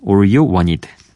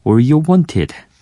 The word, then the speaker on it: All You Wanted: 어, 미국 발음으로는 All You Want It. 그리고 영국 발음으로는 All You Want It. 예, 이런 발음이 되겠죠. 자, 제가 뭐 영어를 잘하는 건 아니지만 늘 재밌어요. 영국 영어는 T 발음을 정확하게 살려주고 어, 미국 영어는 조금 더 굴려준다는 게 재밌는 것 같아요.